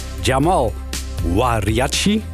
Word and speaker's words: Jamal Wariachi: Het is een stuk geschreven door Jamal Wariachi. Als ik het goed heb uitgesproken, ja, redelijk hè Jamal?